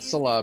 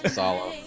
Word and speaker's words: Saleh: Yeah, it's not Salah, people. It's Saleh. You don't need people going, praise Saleh here. It's Robert Saleh.